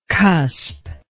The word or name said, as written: cuspal